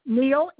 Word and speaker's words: Neill: Neil, N-E-I-L-L.